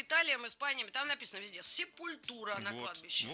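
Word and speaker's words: Sepultura: Сепультура — это вот неправильное произношение. Точнее, это правильное произношение для нас и для, собственно, самой Сепультуры. А многие, значит, ребята, которые думают, что они знают больше всех, Sepulture говорят. Нет такой группы.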